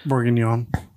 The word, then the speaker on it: bourguignon: Bourguignon.